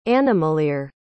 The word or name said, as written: animalier